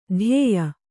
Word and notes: ಧ್ಯೇಯ: kannada; dhyēya

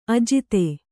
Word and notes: ಅಜಾತ: Kannada

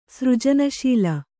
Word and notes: ಸೃಜನಶೀಲೆ: sřjana śila

